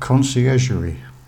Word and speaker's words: Conciergerie: Prisoners of Conciergerie.